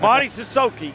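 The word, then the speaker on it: Mady Sissoko: Maudie Sissoko.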